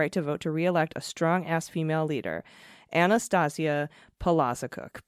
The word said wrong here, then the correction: Palaszczuk: pronounced Palaszczuk. Okay, totally off base there, Palaszczuk. – That was awesome, though, that there was a correction right after you were like Palazikuk. – Palazikuk. Nope, Palaszczuk.